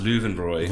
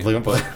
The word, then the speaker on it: Löwenbräu: It's pronounced Löwenbräu. – Löwenbräu.